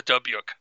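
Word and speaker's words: Dybbuk: So maybe, you know, some of these ones that we're seeing from other countries, you know, maybe you've seen the Dubyuk.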